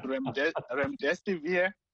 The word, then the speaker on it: Remdesivir: It's called Remdesivir.